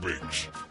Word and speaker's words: Biggs: I am your host, Mr. Biggs. It's spelled with two G's. It's B-I-G-G-S.